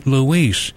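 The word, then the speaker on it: Louiss: Eddie Louis. His last name is spelled L-O-U-I-S-S. L-O-U-I-S-S, Eddie Louis.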